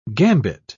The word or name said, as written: gambit